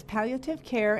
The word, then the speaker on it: palliative care: Now, palliative care, is that the correct way to say that? – Yes, that is palliative care.